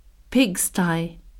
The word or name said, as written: pigsty